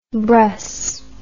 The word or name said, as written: bruss